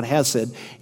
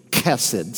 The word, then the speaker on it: chesed: People mispronounce it all the time. It's not hesed, it's kesed. Say that, kesed.